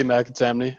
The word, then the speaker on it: McAtamney: But I'm Brady McAtamney.